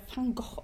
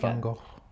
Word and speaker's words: Van Gogh: In jeglicher Doku fällt mir auf, dass es ja Van Gogh ausgesprochen wird. Ich mag das äh, starke V irgendwie nicht. – Achso, nee, Van Gogh.